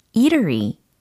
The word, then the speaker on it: eatery: eatery, eatery. 네, 이탈리가 아니라고 했어요. 이럴리가 아니고 음식점에 해당하는 철자였고 발음이었습니다. 어,